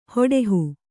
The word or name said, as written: ಹಡುಹು